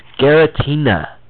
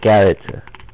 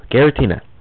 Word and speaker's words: Giratina: And, you know, they could have didn't say this right, but it's Giratina. Not Garita. No, Giratina.